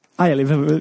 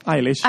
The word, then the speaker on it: Eilish: Det där var då Wish You Were Gay med Billie Eilish. Uttalade det rätt? Eilish? Eilish. – Eilish. – Eilish. – Ja.